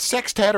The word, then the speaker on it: sextet: With the Ross Wooldridge's Benny Goodman Sextet. Is that how you say it? Is it sextet or is it a weird? – No, it's sextet.